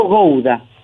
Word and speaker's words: Gowda: ¿Cómo, decime cómo se pronuncia bien tu apellido, por favor. – Mi apellido Gouda.